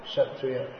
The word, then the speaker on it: Kshatriya: People say, more like shat, kshatriya. Well, it is pronounced. No, it's R-I. Kshatriya.